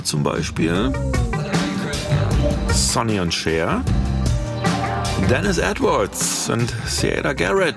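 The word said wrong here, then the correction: Siedah Garrett: Moment, stopp. Zurückgespult. Saida Garrett, habe ich neulich gelernt.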